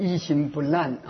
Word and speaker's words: 一心不乱: 他说我这个发音有错误啊，我在这里说明一下呢，那个一心不乱，那个我这个发音是不标准的，我再念一遍，一心不乱啊。